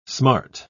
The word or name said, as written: smart